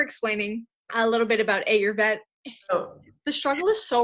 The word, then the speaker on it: Ayurveda: It's, we usually say I, like your I, okay. Ayurveda. – Ayurveda. Okay. Ayurveda. – Like that's how you would pronounce it.